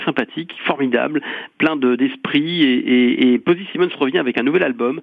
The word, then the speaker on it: Posy Simmons: Il y a aussi le retour de, j'espère que j'ai pas écorché son nom, Posy Simmons. – Ah, Posy Simmons, oui, c'est formidable.